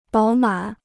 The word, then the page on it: 饱满: bǎo mǎn